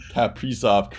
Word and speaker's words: Kaprizov: Kaprizov. – Kirilli Kaprizov.